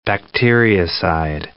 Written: bacteriocide

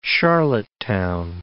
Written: Charlottetown

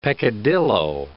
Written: peccadillo

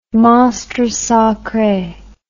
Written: monstre sacre

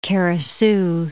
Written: Kara Su

